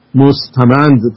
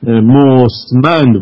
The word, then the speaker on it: مستمند: که به غلط البته اکثر مستمند تلفظ میکنن، ولی اون مستمند باید تلفظ بشه.